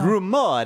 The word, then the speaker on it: Rumore: All I know is you're supposed to pronounce every vowel. – Oh. – So just, you know. – Rumore. – There we go. There it is.